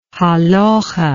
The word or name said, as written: halakah